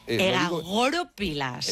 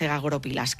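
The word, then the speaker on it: egagrópilas: Egagoropilas. – Egagoropilas. Vamos a poner el acento en la O.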